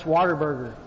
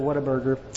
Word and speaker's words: Whataburger: You know you're in the right place when you hear Whataburger pronounced Waterburger. I'm telling you, that's what I thought it was for the first 20 years of my life, but it's actually Whataburger.